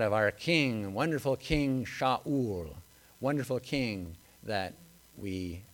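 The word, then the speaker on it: Sha'ul (שָׁאוּל): But Saul's name actually in Hebrew is two words. It's pronounced Sha'ul. And Sha'ul means asked for.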